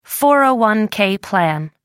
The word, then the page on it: 401-k plan: American English